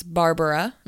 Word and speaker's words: Barbra: It's not Barbara. It's Barbara.